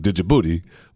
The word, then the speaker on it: Djibouti: I know that, but I cannot stop saying Djibouti because it's got the D in front of it, and I know the D is silent. Just I'm not making fun of you. I just can't stop saying Djibouti when I think about it because that's what it is.